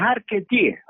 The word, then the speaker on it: archetier: y es arquetier. Es el que hace los arcos. Se escribe archetier, se escribe. La palabra francesa, como luthier François, también.